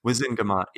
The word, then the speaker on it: Wizengamot: And it's it's the entire how do you pronounce Wizen Wizengamot. Wizengamot, yeah, yeah.